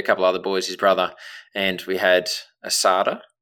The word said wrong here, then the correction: asado: Asado. – Is that how you pronounce it? – Asado. – Okay, so I'll pronounce it Australian way, asada.